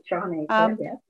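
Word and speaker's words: Shawn Achor: Is that how you pronounce it? – That's right. Sean Achor.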